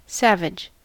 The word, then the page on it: savage: American English